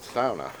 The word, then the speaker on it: sauna: Well, apparently, it's a Finnish word. And Americans kind of just got lazy with it and just called it a sauna. But in its true Finnish form, it is pronounced sauna.